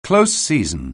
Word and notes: close season: anglais

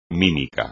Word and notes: mímica: Spanish

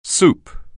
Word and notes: soup: anglais

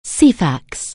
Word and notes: Ceefax®: anglais

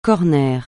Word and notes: corner: francais

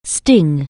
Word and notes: sting: anglais

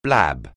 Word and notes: blab: anglais

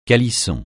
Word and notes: calisson: francais

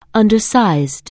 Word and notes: undersized: anglais

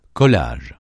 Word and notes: collage: francais

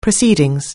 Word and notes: proceedings: anglais

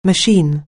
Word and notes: machine: anglais

